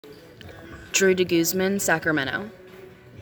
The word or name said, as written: Drew DeGuzman